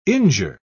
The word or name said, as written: injure